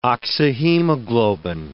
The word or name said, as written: oxyhemoglobin